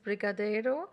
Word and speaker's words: Brigadeiro: Danke für dein Mitbringsel, das Brigadeiro.